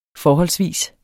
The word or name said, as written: FORHOLDSVIS